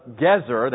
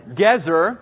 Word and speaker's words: Gezer: He said, I predict that the individuals who excavate the city of Gezer, that's how I pronounce this word, not geezer, that has other connotations. So, Gezer,